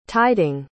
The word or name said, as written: tiding